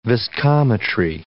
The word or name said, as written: viscometry